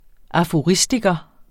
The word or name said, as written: aforistiker